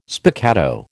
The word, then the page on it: spiccato: American English